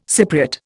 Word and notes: Cypriot: Indian

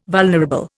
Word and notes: vulnerable: Indian

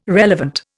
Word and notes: relevant: Indian

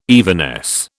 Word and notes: evanesce: British English

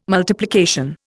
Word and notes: multiplication: Indian

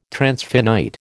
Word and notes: transfinite: American English

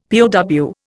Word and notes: POW: Indian

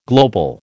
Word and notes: global: American English

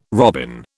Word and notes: robin: British English

